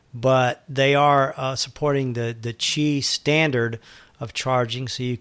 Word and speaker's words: Qi: And it's oddly enough pronounced Qi.